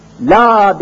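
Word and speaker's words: la (لا): La demek Arapça hayır. Kabul edilmemiştir, reddedilmiştir. La. Lam elif. Hani ismi lam elif okunuşu la diye okunuyor. Okunurken la diye okunan, yazıldığı zaman da ismi lam elif olan bir harftir.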